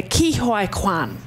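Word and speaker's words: Ke Huy Quan: It's Ki Hoi Kwan.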